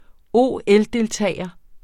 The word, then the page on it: OL-deltager: ˈoˀˈεldelˌtæˀjʌ; ˈoˀˌεl-